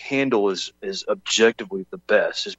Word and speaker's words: Handel: And I know some people say Handel, but I just looked up the pronunciation, how he would have said it in German, and it's Handel. Handel. So there is an emphasis on the EL.